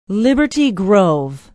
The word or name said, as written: liberty grove